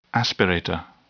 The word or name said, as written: aspirator